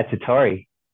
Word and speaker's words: Tottori: He's originally from East Bay in California, and now he's in Tottori, Japan. Is that how you say it? – That's exactly right. Yeah, Tottori.